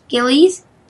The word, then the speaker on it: Gillies: Oh, my favorite character is Maya animated by Coco Jack Gillies. Don't get it confused with Lilies. But if you suffer from in pronouncing G disorder, it's Gillies, like the fish, Gillies.